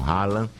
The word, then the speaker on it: Haaland: Então, a Noruega tem um bom time, tem um dos melhores centroavantes mesmo, nato do futebol europeu, chama-se o Haaland, a gente pronuncia aqui como Haaland,